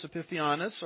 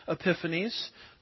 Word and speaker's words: Epiphanes: It, that's the little horn, Antiochus Epiphanes, or Epiphanes, depending on which syllable gets the emphasis, as I like to say.